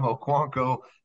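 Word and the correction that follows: Okuayinonu: I believe is the way he pronounces it. – Okwonyo. – Okwonyo, like I said, yes. – You threw a K in there. You're still hung up on Chig right now.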